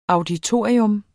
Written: AUDITORIERNES

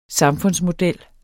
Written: samfundsmodel

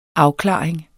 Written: AFKLARING

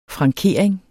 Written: FRANKERINGER

